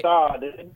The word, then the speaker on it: Saad: Saad. All right. – Saad.